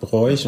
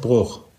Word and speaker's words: Broich: Aber Tim sagt Bräuch und du sagst Bruch. Da hört man dann den Eifler. – Aber heißt das nicht, das schreibt man doch auch Bräuch. B-R-O-I-C-H. – Ich meine, laut Lautschrift, dingsbumsmäßig wäre es dann ein langes O, aber ich kann das nochmal genau aufrecherchieren.